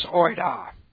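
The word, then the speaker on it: oida (οἶδα): Active indicative of the root word is oida. This is the root word. O-I-D long E. Oida.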